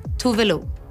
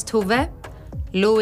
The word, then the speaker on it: Tove Lo: Hi, if there's still confusion around how to pronounce my name, this is how you pronounce it. Tove Lo. Tove Lo. My name is Tove. Lo is my nickname. You put it together, Tove Lo.